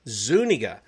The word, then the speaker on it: Zuniga: Now, he's corrected me on the pronunciation. We've known each other for years, and this is the first time I've gotten the correction. So I'm hoping I remembered that correctly. Zuniga.